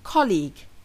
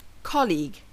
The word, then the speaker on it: colleague: I often hear learners say clique, which can sound quite odd for non-Malaysian English speakers. This is my clique, ama, for example. The correct pronunciation of the word has two syllables, not one, and the stress falls on the first syllable. Colleague. Colleague.